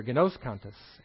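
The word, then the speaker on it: gnoskantes (γινώσκοντες): Now, knowing in this particular verse is, I'm going to have to pronounce this carefully here, Gnoskantes, and the emphasis is on the O there, gnoskantes.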